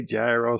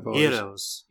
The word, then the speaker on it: gyro: Some people say gyro, some people say hero. – Heroes. – Heroes, yeah. Heroes. This is the correct way to pronounce that.